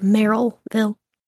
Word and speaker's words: Merrillville: So when they received this tip, Maryville police thought, I'm saying, like, Maryville. That's not how you say it. It's, like, Merrillville.